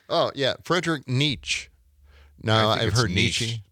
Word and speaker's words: Friedrich Nietzsche: Now, his name in German yes. – would normally be pronounced Friedrich Nietzsche. Friedrich Nietzsche. However, in English, his name is normally pronounced Friedrich Nietzsche.